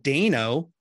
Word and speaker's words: Dano: Paul Dano, which I found out is how you really pronounce the last name, go figure, as the Riddler.